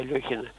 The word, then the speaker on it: Алехин: Все говорили Алехин. Там раз 10-15 произносили Алехин. Потом у меня вот старенький еще словарь, довольно советский энциклопедический словарь, 1987 года. Совершенно четко написано «Алехин».